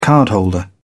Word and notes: cardholder: British English

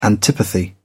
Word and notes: antipathy: British English

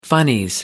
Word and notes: funnies: British English

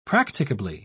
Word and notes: practicably: British English

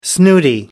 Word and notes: snooty: American English

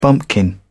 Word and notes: bumpkin: British English

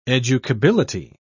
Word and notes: educability: British English

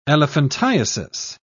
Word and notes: elephantiasis: British English

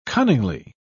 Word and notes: cunningly: British English

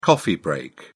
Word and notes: coffee break: British English

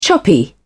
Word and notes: choppy: British English

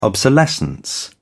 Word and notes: obsolescence: British English

